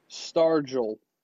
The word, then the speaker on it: Stargell: Stargell.